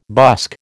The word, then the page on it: busk: American English